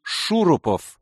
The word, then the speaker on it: Шурупов: А полностью Михаил Геннадьевич Шурупов, с ударением в фамилии на первом «у», что, впрочем, не помогало. Все по привычке ставили ударение на втором слоге.